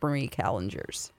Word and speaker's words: Marie Callender's: Most people would call it Callender's, but my mom says Marie Callender's, and we lived in California, and I know the first restaurant is by the Queen Mary, so I think it's pronounced Marie Callender's.